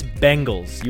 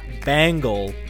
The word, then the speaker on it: Bengal: It's Bengals. You always say it wrong. You always say Bengals. – How are we saying it different? Bengals. – It's a Bengal, like Ben, like Uncle Ben. Bengal, not Bangle, like Dangle.